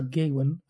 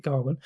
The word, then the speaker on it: Gawain: So The Green Knight is a fantasy retelling of the medieval story of Sir Gawain and the Green Knight, not Sir Gawain, as I thought it was until I actually watched the film and then realized they're pronouncing it Gawain.